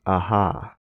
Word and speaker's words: Aja: He is a Spanish artist, so it might be pronounced Aja. Okay, I'm going to go with David Aja. David Aja.